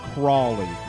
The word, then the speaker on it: Crowley: So Mr. Crowley is referring to Aleister Crowley, and it's actually mispronounced. It was supposed to be Crawley, is the way his name was actually said.